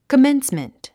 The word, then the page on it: commencement: kəménsmənt; コメンスメント